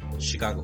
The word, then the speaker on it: Chicago: Chicago.